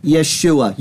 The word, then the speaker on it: Yeshua: What is so surprising is that the host of the video pronounced the name of Jesus in Hebrew in its full and correct form. Yeshua.